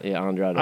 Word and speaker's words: Andrade: Number three is the fact that I got Andre El Idolo back. I hope I said that right. – Andrade? – Andrade.